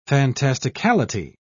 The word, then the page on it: fantasticality: British English